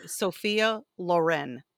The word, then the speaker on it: Sophia Loren: Actually, you say her name, Sophia Loren.